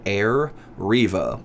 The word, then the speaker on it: Arriva: It's spelled Arriva, but it's called Arriva.